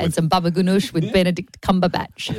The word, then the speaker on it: Benedict Cumberbatch: Benedict Cumberbatch. – Benedict Cumberbatch. – You didn't even say it right. – Antonio Benedict Cumberbatch. – Benedict Cumberbatch. – Yeah, there you go.